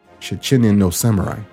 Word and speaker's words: Shichinin no Samurai: And if I could speak Japanese, I'd say it was Shichinin no Samurai is the proper pronunciation of that original movie.